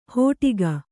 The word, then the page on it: ಹೋಟಿಗ: hōṭiga